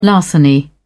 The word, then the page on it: larceny: British English